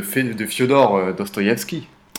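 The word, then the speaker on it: Fiodor Dostoïevski: De Fyodor Dostoïevski. – Oui, tout à fait. Bien, très bien prononcé.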